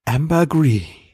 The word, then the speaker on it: Ambergris: Letzte Woche habe ich noch gerätselt, ob The Ambergris oder Ambergris heißt und beides war falsch. Das Wort wird einmal kurz in der Folge ausgesprochen von einem Charakter und es heißt Ambergris.